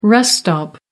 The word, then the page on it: rest area: British English